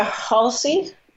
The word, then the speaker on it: Halsey: I listened to Halsey.